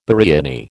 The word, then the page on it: biriani: American English